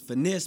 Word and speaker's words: Fynesse: It's Finesse spelled with a Y.